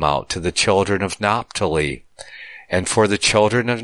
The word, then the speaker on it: Naphtali: So that's how we would pronounce it, Naphtali, with a F. According, the PH would be pronounced as an F, that is.